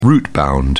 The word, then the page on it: rootbound: British English